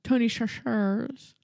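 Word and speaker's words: Chachere: Or what's the actual pronunciation of that name? – Chachar. Tony Chachar. – Tony Chachar? – Well, you don't say it like that, but yeah, that's about it.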